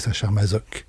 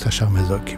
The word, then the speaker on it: Sacher-Masoch: Donc je pense que le mieux, c'est de prendre la prononciation actuelle en française, qui est Sacher-Masoch. C'est probablement la prononciation qu'a imposée de toute façon Krafft-Ebing en inventant le mot de masochisme. Donc Sacher-Masoch, et puis voilà.